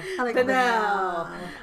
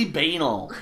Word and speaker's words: banal: It's banal. It's totally banal. – It's like anal with a B.